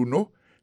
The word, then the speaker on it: know: Knowledge, c'est K-N-O-W-L-E. D-G-E, knowledge, ça veut dire connaissance. Il sortit du verbe to know, qui veut dire savoir. To know, on a songé que c'est K-N-O-W. Alors, ça que je ne vais pas prononcer K, c'est parce que K, il est silencieux.